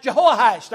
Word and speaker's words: Jehoash: If you go back to the book of 2 Kings, his name is pronounced Jehoash. There's a H-O in there in the middle.